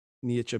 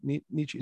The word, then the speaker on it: Nietzsche: Nietzsche being Friedrich Nietzsche. Am I saying that right? Nietzsche. A lot of people say Nietzsche. – I say Nietzsche.